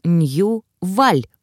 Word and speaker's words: Нью-Валь: Нью-Валенская империя или Нью-Валь произносится как Нью-Валь, ударение на последний слог.